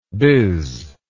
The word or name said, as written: bizzer